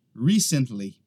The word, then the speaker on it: recently: O que acontece é que às vezes algumas pessoas pronunciam ela recently. Eles acabam colocando a ênfase no lugar errado. É na primeira sílaba. Recently. Recently, tá?